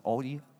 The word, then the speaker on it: Audi: Audi, how do you pronounce it? Audi.